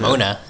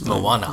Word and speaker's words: Moana: It's not Mona, it's Moana. Moana.